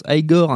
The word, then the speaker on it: Igor: qui se prononce Igor,